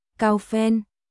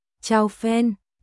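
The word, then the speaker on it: chao phen (炒粉): Xào, đọc nhướn lưỡi lên. Cháu đọc thành âm thẳng lưỡi. Kiều Thần nói, ngủ với phen, trong tiếng Trung là thảo phấn, cao phen.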